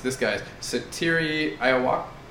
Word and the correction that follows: Satoru: The re-elect Satoru Iwata, who is the president of Nintendo.